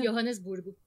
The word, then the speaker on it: Joanesburgo: E a maior cidade de lá é a Joanesburgo. É assim que se pronuncia? Johannesburgo. Johannesburgo, com quase 5,7 milhões de pessoas.